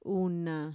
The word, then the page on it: una: Mixtec